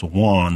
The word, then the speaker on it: Juan: And so it's spelled J-U-A-N and pronounced Juan, of course, but Byron makes you say Juan.